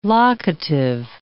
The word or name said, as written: locative